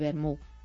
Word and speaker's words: vermú: Las conservas de calidad y el vermú. Vermú, como dicen ellos, sin té y con acento en la U.